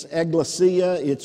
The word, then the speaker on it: ekklesia: I've looked it up and I listened to it. It's ekklesia. It's a word that was a Greek word, and he used this term, ekklesia, talking about the church.